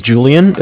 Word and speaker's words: Julian: My name is Julian. It's spelled J-U-L-I-A-N. And, calling from, Bank of Cardiff. It's spelled C-A-R, D as in dog, I-F-F as in Frank Frank.